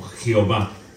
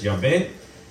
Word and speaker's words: Yahvé: Entonces por primera vez se muestra el nombre de Dios, el cual como veíamos se eh, translitera y latiniza como Jehová, como nombre Jehová. Más correctamente es Yahvé pero